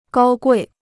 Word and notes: 高贵: gāo guì

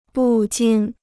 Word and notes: 不禁: bù jīn

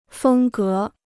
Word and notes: 风格: fēng gé

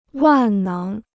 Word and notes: 万能: wàn néng

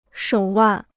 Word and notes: 手腕: shǒu wàn

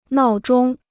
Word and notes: 闹钟: nào zhōng

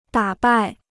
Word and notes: 打败: dǎ bài

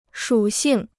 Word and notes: 属性: shǔ xìng

